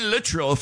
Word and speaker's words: Littrell: Can with a K, Brian Littrell from the Backstreet Boys. Yeah, that's how it's pronounced.